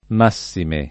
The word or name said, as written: massime